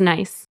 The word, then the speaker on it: Nice: So the city in the south of France, how do you say that? – Nice. – Okay, just making sure that I didn't have that one wrong too.